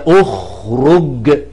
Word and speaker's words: اخرج: الالف دي تشكلها بايه؟ قالوا بنشكلها بتشكيل الحرف الثاني، هي يخرج، الره عليها ضم، يبقى الضم دوت ينط قبل الخاء ويتحط على الالف تبقى اخرج.